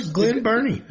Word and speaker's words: Glen Burnie: Glen Burnie, Maryland. – Glensborn and then Glenborn. Oh. I don't know if I pronounce it. – Yeah. No, you did great, bro. Glen Burnie.